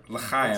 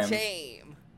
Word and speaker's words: L'chaim: How do you say that word? – L'chaim. – L'chaim.